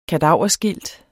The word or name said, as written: kadaverskilt